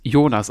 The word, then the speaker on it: Younas: Dein Name, der schreibt sich Younas und trotzdem wird es Jonas ausgesprochen.